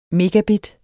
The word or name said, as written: megabit